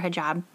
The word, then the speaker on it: hijab: Like, there was a woman in one of my grad classes that I was talking to, and she wore a hijab.